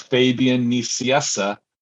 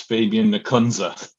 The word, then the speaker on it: Nicieza: In English, it's Fabian Nicieza, and in American, it's Fabian Nicunza. – Can I just stick with Fabian? – You certainly can, but you feel free to pronounce it Fabian Nicunza if you were born in the United States.